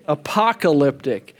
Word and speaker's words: apocalyptic: And so point number two, the book of Revelation is written in apocalyptic. It's a short A.